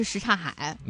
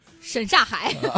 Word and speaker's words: 什刹海: 所以我们真的要给大家普及，一个是这个大石拉，一个是什刹海。 – 嗯，什刹海会读成什么呢？ – 沈刹海。